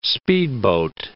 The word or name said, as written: speedboat